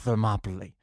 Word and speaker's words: Thermopylae: Is that how you pronounce Thermopylae. – It? – Thermopylae. – Thermopylae. – Yes.